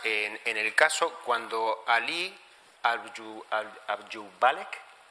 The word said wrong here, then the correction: Abdulhalek: no lo pronuncio bien el apellido, Abdulhalek, vale, eh, va a luchar, eh, piensa en la...